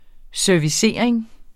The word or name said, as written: servicering